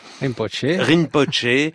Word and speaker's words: Rinpoche: Ce jour-là, j'ai rencontré, j'espère bien le prononcer, Kanju Rinpoche. – Rinpoche.